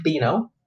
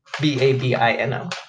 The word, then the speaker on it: Babino: I always say Bino because it's B A B B A B I N O, but it's Babino because it's a Cajun name.